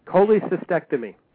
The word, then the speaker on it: Cholecystectomy: Cholecystectomy.